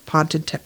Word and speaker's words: potentate: Potentate, potentate, potentate, potentate, the King of Kings and Lord of Lords.